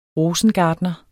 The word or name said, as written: ROSENGARTNER